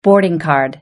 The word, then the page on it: boarding card: British English